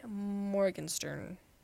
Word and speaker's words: Morgenstern: Okay, my sixth favorite book is The Night Circus by Erin Morgenstern.